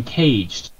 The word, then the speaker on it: Cage: Maybe that they got tired of being caged, so to speak. – That was a dark pun there. – I appreciate the pun, but as he's a Frenchman, the official pronunciation of his name is Cage.